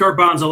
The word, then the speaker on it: Garbanzo: It's Garbanzo, like the garbanzo bean.